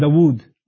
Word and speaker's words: Dawood: The Hebrew version is David and Dawood. David and Dawood, that's how they pronounce it, okay?